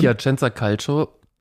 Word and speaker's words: Piacenza Calcio: Hast du richtig schön ausgesprochen. – Dankeschön. – Piacenza Calcio